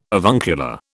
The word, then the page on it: avuncular: British English